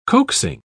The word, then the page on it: coaxing: British English